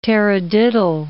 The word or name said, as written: tarradiddle